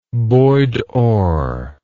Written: boydorr

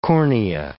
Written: cornea

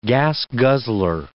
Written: gas-guzzler

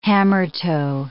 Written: hammertoe

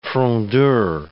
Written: frondeur

